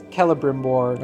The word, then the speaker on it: Celebrimbor: Well, the thing that I think Tolkien emphasized with elves, elvish names, is that if it begins with a C, it's pronounced with a K if it has a vowel after it. – Okay. – So for me, it's Celebrimbor. – Okay, Celebrimbor.